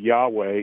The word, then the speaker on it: Yahweh: It's fascinating that as you trace the use of the name of the Lord, Yahweh is the best way we can pronounce the four consonants that were given in Hebrew.